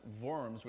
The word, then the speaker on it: Worms: And Worms isn't, the actual little squiggly things that we're thinking of. It's actually pronounced Worms, which is a town in Germany.